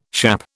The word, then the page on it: schappe: British English